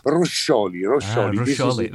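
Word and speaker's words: Roscioli: It's Fabio Roschioli. I don't know if I've said that right. – Roschioli is exactly how they say in Germany also, because I live between Italy and Germany. Roschioli.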